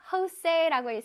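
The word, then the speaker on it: José: 왜 멕시코에서는 J 발음을 H 발음으로 하거든? 그래서 Jose라고 읽습니다.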